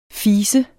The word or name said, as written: fes